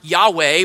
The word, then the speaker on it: Yahweh: The Hebrew four-letter name for God, YHWH, how we would pronounce Yahweh, well, they wouldn't have said that.